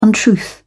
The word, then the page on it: untruth: British English